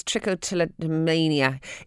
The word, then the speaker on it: trichotillomania: And TTM, which is, I can never pronounce it, it's trichotillomania, is a form of OCD.